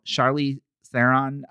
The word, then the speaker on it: Charlize Theron: I just, you know, Charlize Theron, did I say her name correctly? – Yeah.